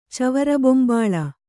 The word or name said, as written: ಚವರಬೊಂಬಾಳ